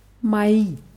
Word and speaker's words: мои: Und jetzt haben wir noch die Pluralform von meine heißt mai. – Mai. – Ja, auch mit drei Buchstaben M-O-I. Mai. Und die Betonung ist auf I.